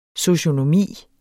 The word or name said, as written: socionomi